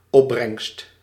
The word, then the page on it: produit: France; [pʁo.dɥi]